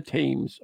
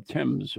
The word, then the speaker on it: Thames: Every new year begins with a phantom who is said to throw himself from Westminster Bridge into the Thames, or Thames, or how do you say it? Mm-hmm. See, Thames, Thames, they can't get it right. – Okay, yeah. – Right? Yep. So Clark is clerk, and Thames is Thames.